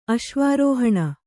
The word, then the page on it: ಅಶ್ವಾರೋಹಣ: Kannada